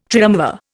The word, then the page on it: triumvir: Indian